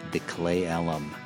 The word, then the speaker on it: Clay Ellum: Their name is a little hard to pronounce if you're not from the Pacific Northwest, so impress your Seattle friends by saying it correctly, the Clay Ellum.